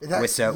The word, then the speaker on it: Wiseau: Wiseau.